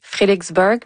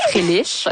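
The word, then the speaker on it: Frelighsburg: Là, vous avez dit Frélixburg. Moi, je viens de Farnham. Nous, on dit Frélich. – Ouais, donc là, je sais pas comment le prononcer, mais donc, cette initiative que vous mettez sur pied à Frélich. – Oui, mais il y a plein de manières de le prononcer. En fait, ça fait pas consensus.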